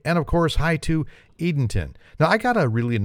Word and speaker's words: Edenton: But this time, I figured I'd find out how the locals pronounce it. – Thank you for calling Visit Edenton. To help us reduce spam calls, please press 1 to reach the front desk. – So there you go. It's Edenton.